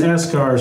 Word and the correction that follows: Beskar: or something. – Beskar. – Beskar. Thank you.